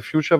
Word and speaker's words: Fuchsia: Ähm, und Google arbeitet ja nun hinter zur Abwechslung mal überhaupt nicht verschlossenen Türen an, an äh, äh Fuchsia beziehungsweise Future wird es ja ausgesprochen,